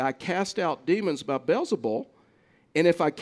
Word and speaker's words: Beelzebul: And you may have heard this pronounced, depending on the different translation, Beelzebul is the way that the ESV renders the pronunciation of that word.